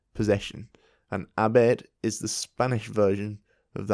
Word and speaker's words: haber: So, that was H-A-B-E-R. So, haber. What you may have guessed by now is that the H, if it starts a word, is not pronounced. Mm-hmm. So, for example, how do you say today? – Hoy. – Hoy. And the spelling is H-O-Y. It's a similar case there. You don't pronounce the H.